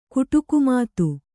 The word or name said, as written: ಕುಟುಕುಮಾತು